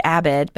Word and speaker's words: Abed: And it's David Abid, but it's spelled A-B-E-D.